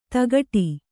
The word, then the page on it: ದಗಡಿ: Kannada